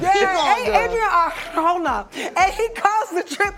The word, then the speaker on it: Arjona: How you say her name? – Adria. – Arjona.